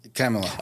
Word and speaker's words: Kamala: Tells me I pronounce her name wrong, so please tell me how to pronounce her name. It's Kamala. Kamala.